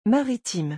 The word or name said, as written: maritime